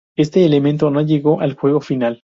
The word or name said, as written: elemento